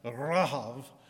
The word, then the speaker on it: Rahab: And again, they send some spies in, and some spies go into Jericho, and there is a woman who is named, we say Rahab, I think in Hebrew it's pronounced Rahav, but I can't do the guttural sound in Hebrew, and because I'm American and a Southern American at that, I'll just call her Rahab.